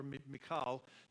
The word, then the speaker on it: Michal: I mean, that's the story of him and then Michael or Michal, depending on how you want to pronounce it.